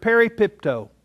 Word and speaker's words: peripipto: The Greek word that is translated fall is the word peripipto. Let me just spell it. P-E-R-I, peripipto, P-I-P-T-O, peripipto.